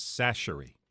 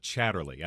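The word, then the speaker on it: Chachere: It's Sachery. Not Chatterley. I have a friend that says Chatterley all the time, and I'm like... – That's the Tony's brand. – That's the Tony's. It's Tony Sachery.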